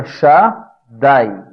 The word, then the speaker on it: Shaddai: é Shakai. E se pronuncia Shaddai.